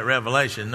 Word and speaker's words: Revelation: It's not Revelations, it's Revelation. Many people mispronounce that. They call it Revelation. No, it's Revelation.